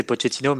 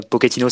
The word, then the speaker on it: Pochettino: Euh, et en italien, Pochettino, ça veut dire un petit peu, donc ça se prononcerait euh, Pochettino si tu veux le faire à l'italienne et Pochettino si tu veux le faire à l'Argentine.